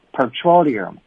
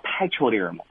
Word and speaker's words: petroleum: 但是大家呢要注意了哈，当它简读的时候呢，要读成的是 petrol。但是呢，它是这个原词啊，你要把它读成的叫做 petroleum 啊，不要读成什么 petroleum 啊，这个发音大家一定要注意一下。